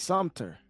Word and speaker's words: Sumter: South Carolina, Summer. The town's name is Summer. Summer is spelt Summer, but the T for an M. S-M-T-E-R. – It's Sumter. – Sumter? Okay, there you go. – Yeah. Sumter, South Carolina.